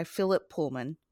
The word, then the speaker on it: Philip Pullman: This episode of Three Reads will be discussing The Golden Compass, part of His Dark Material trilogy series by Philip Pullman.